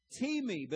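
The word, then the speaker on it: timi (τιμή): Honor comes from the Greek word timi, but it's spelled time, T-I-M-E. But it's pronounced timi.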